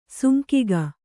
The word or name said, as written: ಸಾಂಗಿಕ